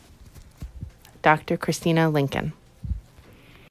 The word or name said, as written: Christina Lincoln